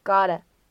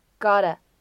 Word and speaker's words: gotta: 再来看一下最后一处，在美式发音当中呢，这个 gotta gotta 它呢也是包含闪音的处理，所以当中的两个字母 t 呢发闪音 flap t，我们呢可以读成 gotta gotta gotta。